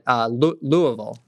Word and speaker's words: Louisville: Including one about Muhammad Ali, which you got to put on in, let's see if I can say it like they say it, Louisville.